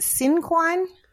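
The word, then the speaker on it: cinquain: And so our students are currently doing poetry and and our juniors are doing, is it cinquine poems? – Cinquine.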